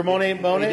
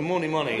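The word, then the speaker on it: Mony Mony: How's it pronounced? – Moni Moni. – Like M-O-N-Y.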